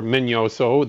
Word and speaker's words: Minoso: And many of these players, like Minnie or Orestes Minnie Minoso, or Minoso, the proper way to pronounce it, have been looked at before and have been skipped over.